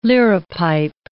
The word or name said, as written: liripipe